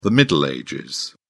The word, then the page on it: middle ages: British English